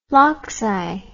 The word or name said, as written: flocci